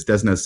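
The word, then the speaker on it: DESNZ: So is that the correct pronunciation now? Desnes. We've been arguing internally about what it is. Desnes seems to have won.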